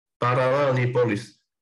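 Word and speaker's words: Paralelní Polis: How do you say that word? It's like the hardest word to say. – Parallelipolis. – There you go.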